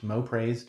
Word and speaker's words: MOPRAYS: It's pronounced mopraise dot org. M O P R A Y S dot org.